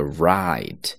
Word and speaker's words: Wright: 注意最后的这个人名字 W 是不发音的，读作 Wright.